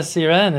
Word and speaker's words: Siren: All right, Kaisa Siren, it's great to have you on the podcast.